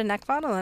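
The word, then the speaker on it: Neckvatal: You can also find me on Twitter, LinkedIn, and YouTube, Brenda Neckvottel. And again, my name is spelled N-E-C-K, like the thing you want to choke, V as in Victor, A-T-A-L.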